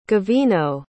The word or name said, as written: gavino